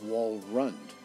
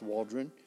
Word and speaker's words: Walrond: Now, we'll get into that a little bit later as to why that changes from Waldrund, W-A-L-R-O-N-D, because that is the original spelling and original pronunciation, and those of you who are familiar with Waldron, you're not incorrect.